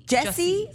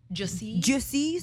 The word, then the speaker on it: Jussie: But I saw something that you posted about, I always say his name wrong. – Jesse Smollett. – But it's just, what is it spelled like in French? Is Jussie Smollett?